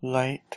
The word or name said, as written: lwait